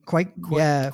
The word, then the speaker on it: Cuoco: Kaylee Cuoco from Big Bang Theory.